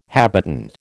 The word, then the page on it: habitant: American English